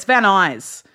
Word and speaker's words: Van Nuys: I was like the King of Van Nuys, but then they pronounce that Van Nuys. – Yeah, it's Van Nuys.